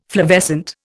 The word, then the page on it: flavescent: Indian